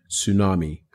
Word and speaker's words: tsunami: So it was me mispronouncing tsunami. Sorry, not Tyrannosaurus. So tsunami. So you know how tsunami, it's silent. It's almost like there's a silent T in tsunami.